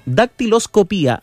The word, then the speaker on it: dactiloscopía: Hoy es el Día Mundial de la Dactiloscopia. No, dactiloscopía. Ahí está, sí, porque tiene acento en la I, o tilde en la I.